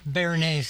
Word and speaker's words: béarnaise: So this béarnaise sauce, I can't pronounce it.